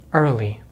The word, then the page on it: early: American English